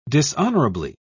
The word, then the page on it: dishonorably: British English